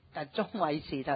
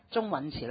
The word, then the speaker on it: 鐘允慈: 咁喺度亦都再一次請返民政事務處嘅聯絡主任主管阿鐘女士，鐘偉慈頭先介紹過，鐘允係呀，真係我啲發音唔正呀。咁鐘允慈女士，請你介紹文件，唔該。